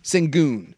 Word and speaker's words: Şengün: Şengün.